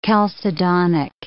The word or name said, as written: chalcedonic